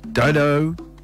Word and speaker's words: Dido: I said it right. It is Dido.